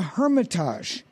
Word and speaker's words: Hermitage: And then Wednesday, after the Shelby Park, Golf Heritage Society golf champions will be playing at the Hermitage.